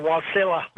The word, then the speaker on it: Wasilla: How do you pronounce it out there? Wasilla? – Yeah, Wasilla.